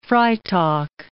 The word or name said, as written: Freytag